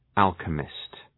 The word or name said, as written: alchemist